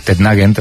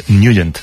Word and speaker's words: Nugent: Hombre, siempre lo hemos llamado en el barrio Tednagent o Tednagen. – Claro, yo era de Tednagen. – Pero la pronunciación correcta es Nugent. – Te fuiste a Washington y descubriste que era Nugent.